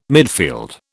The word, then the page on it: midfield: British English